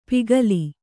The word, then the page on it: ಪಿಗಲಿ: pigali